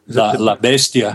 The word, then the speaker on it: La Bestia: One that strikes me the most is La Bestia. Did I say that right? – La Bestia.